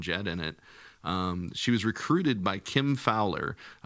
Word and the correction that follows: Fowley: Fowley? – Mm-hmm. – Oh, okay. I've got the name wrong. Sorry.